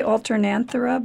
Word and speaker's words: Alternanthera: I tried, I might be wrong on this, Alternanthera, is that how you pronounce it? – I say Alternanthera, but it doesn't matter.